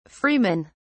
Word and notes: freemen: British English

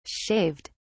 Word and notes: shaved: British English

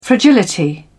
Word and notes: fragility: British English

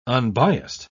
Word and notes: unbiassed: British English

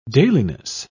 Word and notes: dailiness: British English

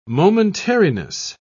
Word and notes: momentariness: British English

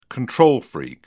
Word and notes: control freak: British English

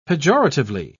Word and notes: pejoratively: British English

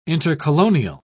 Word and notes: intercolonial: British English

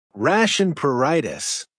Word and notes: rash and pruritus: American English

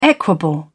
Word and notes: equable: British English

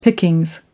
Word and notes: pickings: American English

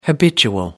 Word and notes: habitual: American English

